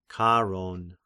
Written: Charon

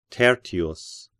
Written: Tertius

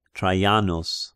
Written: Traianos

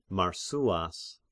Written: Marsyas

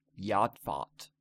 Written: Jodphat

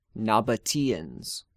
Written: Nabateans

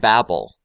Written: Babel

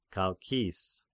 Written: Chalkis

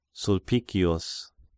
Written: Sulpicius

